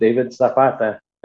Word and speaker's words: David Zapata: David Zapata.